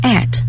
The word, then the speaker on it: ate: Eat 呢有一个过去式，读法有两个，一个是英音，一个是美音。英音呢读成 at， eat at，美音呢读成 ate， ate， A-T-E， ate， eat， ate，或者是 at 都可以。